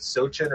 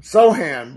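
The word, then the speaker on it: Sochan: Sochin or Sohan? – Sohan, but I honestly didn't know how to pronounce it until tonight.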